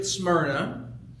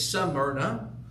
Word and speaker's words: Smyrna: It's the church at Smyrna. Unless you're from more Warren County and it's Smyrna. Don't know why they pronounce whatever.